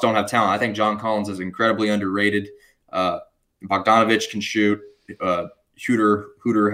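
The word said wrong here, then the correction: Huerter: however you say his name. – Herter, Kevin Herter.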